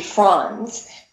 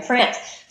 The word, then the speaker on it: Franz: And I was single, so they said uh, my maiden name is is actually Franz, but everyone in America pronounces it France. So all my life I've been before I was married Erin France.